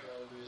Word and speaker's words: Alleluia: Because the Hebraic pronunciation for Alleluia is Alleluia. The accent is on the final syllable.